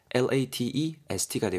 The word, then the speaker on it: latest: 레이리스트 LATEST가 되고요. 발음은 latest 정도로 해주시면 좋을 것 같습니다.